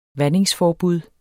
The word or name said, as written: vandingsforbud